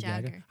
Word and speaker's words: Jagger: Moves like Jagger. I said it right. – You said Jagger. – Jagger. – Moves like Jagger. – Jagger. – Jager. I was thinking of Jägermeister.